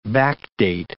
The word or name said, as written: backdate